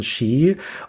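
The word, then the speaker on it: Sídhe: Xi spricht man das aus. – Xi spricht man das aus? – Ja, das ist ja ein Originalname aus der keltischen Mythologie.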